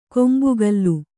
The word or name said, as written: ಕೊಂಬೆಗಲ್ಲು